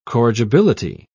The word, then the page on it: corrigibility: British English